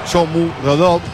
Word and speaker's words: Shomurodov: Caso Shomurodov, así se pronuncia, Shomurodov, es difícil ese.